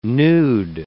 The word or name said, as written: nude